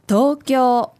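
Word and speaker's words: 東京: Is it three syllables or two syllables? – Tokyo. So two syllables. – Mm-hmm. And both are long vowels.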